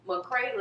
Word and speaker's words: McCray: My Facebook at Jasmine McCray.